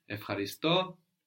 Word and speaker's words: ευχαριστώ: Ευχαριστώ, I thank, ευχαριστούμε, we thank. So in ευχαριστώ, we have EU pronounced F, as in εφορία, euphoria.